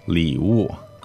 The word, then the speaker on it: 礼物: So third tone, fourth tone, right? 礼物.